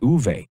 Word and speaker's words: Uwe: Uwe Krupp, the overtime winner in game four. I think it's pronounced Uve.